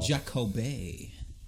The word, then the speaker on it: Jacoby: Jacoby. I think that's how they actually pronounce it. – Is it? – Yeah, it's French.